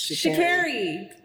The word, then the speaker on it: Sha'Carri: I will say, just because I had to watch her video a few times mm-hmm. to make sure I say it properly, it's Sha'Carri. It's Sha'Carri.